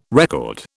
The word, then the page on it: record: British English